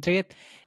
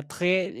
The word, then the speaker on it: Triet: Como bien lo decía usted, es una película francesa dirigida por Justin Triet. Se pronuncia Tré en francés, espero haberlo pronunciado bien.